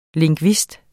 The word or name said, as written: LINGVISTER